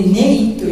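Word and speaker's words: 婦負: で、ネイというのはどんな字を書くかっていうと難しいですよね。ご婦人の婦という字、それに負けると書くんですね。婦人が負けると書いてネイと読むのです。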